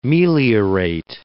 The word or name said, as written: meliorate